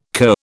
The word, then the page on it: cur: British English